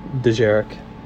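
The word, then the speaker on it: dejarik: And then we cut to Chewie and Beckett playing, how do you pronounce that little chess game? – I don't know what that one's called, but... – Like, it's like Dirgeric or Dirge? – Let me see. – Dijaric? – Dijaric.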